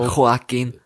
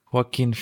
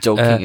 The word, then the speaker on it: Joaquin: Ja, Joaquin. Joaquin Phoenix. – Joking ist ja falsch.